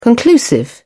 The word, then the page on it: conclusive: British English